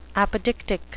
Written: apodictic

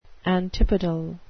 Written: antipodal

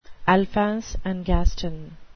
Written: alphonse and gaston

